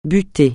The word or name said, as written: buté